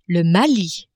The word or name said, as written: Mali